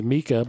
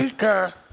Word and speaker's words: Micah: His name is Mika, but it's spelled Micah. – Mika and Katie.